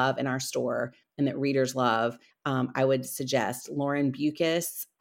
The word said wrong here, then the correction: Beukes: I never know if I'm saying her right, saying her name right. It's Beukes.